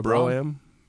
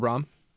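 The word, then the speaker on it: Brougham: The Ale House on 1st and Royal Broham. – Yep, Royal Brom. – It's not Broham? – That's how it's spelled. but it is Royal Brom.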